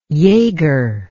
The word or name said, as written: yager